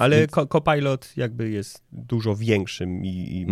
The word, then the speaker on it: Copilot: Copilot.